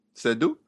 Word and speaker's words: Seydoux: but instead we have Leah how do you say? – I'm going to go with Seydoux. – Seydoux.